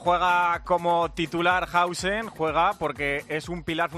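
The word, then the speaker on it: Huijsen: Bueno, atención, porque el Madrid firmado tiene a Hausen, como dicen ahora. ¿Se dice así, Melchor? – Hausen, perfecto.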